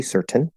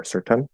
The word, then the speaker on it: certain: That's fine, um, or certain. See, it sounds weird if I try to do a schwa. I like eh, but um, the way it's most commonly pronounced is with a, a glottal stop T.